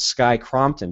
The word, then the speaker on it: Sky Crompton: It's Sky Crompton.